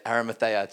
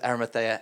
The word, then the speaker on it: Arimathea: In fact, we see later on in John chapter 19 or 20, when Jesus dies and you have Nicodemus and you have Joseph of, help me, Arimathea. They helped me last service too. Like, I have to learn how to say it. Arimathea, Joseph of Arimathea and Nicodemus, they take the body of Jesus.